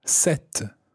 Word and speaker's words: cette: Now, the feminine version of this is cette and it's spelled C E double T E. Set.